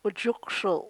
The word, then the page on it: ujukshul': chukchansi